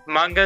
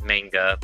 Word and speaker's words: manga: Manga is the correct way to say it, but it can also be said as manga.